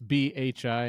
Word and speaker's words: Bhi: Um, but it's B H I, and that's pronounced B. It's like a silent H, and the I is kind of an E sound.